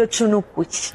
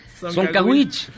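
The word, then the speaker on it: Dzoncauich: Y Yucatán tiene un municipio de La Esperanza que es de Ochunucuch. – La D no suena, tendría que ser Soncaguich.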